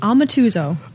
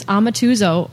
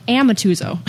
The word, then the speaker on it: Amatuzzo: So it's, for the record, it's Amatuzzo. Or if you're in Western New York, Amatuzzo.